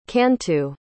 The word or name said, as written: cantu